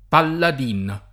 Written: Palladin

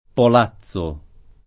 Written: Polazzo

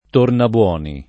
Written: Tornaboni

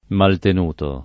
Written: maltenuto

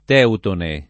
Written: teutone

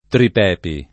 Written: Tripepi

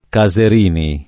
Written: Caserini

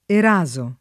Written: eraso

